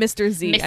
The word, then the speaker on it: Zy: It's spelled Z-Y, but they call him Mr. Z.